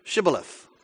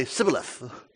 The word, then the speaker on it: Shibboleth: Then they would say to him, well, would you pronounce Shibboleth? And he would say Sibboleth, for he could not pronounce it right. If they said Sibboleth instead of Shibboleth, they were killed.